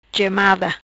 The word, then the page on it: Gemada: patua